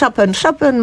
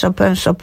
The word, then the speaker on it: Chopin: Dowolnie. Może być Chopin, może być Chopin.